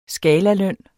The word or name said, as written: skalaløn